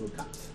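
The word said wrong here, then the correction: Ul'cot: Ulcott. – Ulcott. It's spelled UL apostrophe COT. – Ulcott.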